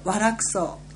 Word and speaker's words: 和楽荘: 平和の和に楽しい、別荘の荘で和楽荘と読みます。